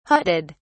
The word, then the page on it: hutted: British English